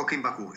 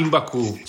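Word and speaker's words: 緊縛: O Kimbaku, esatto. Kimbaku, cioè proprio tutti gli ho sbagliati gli accenti.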